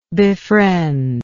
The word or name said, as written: befriendee